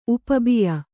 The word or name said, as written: ಉಪಬಿಯ